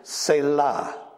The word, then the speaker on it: selah: And so if you want to know how it's pronounced, it's selah.